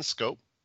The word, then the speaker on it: Schoop: So next up, Jonathan Schoop. And yes, that's how you pronounce it.